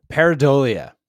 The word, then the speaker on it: pareidolia: Pareidolia is what we're doing this week.